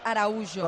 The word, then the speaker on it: Araújo: Es que nosotros pronunciamos, intentamos pronunciar lo mejor posible, y Oli me ha dicho que es Araújo.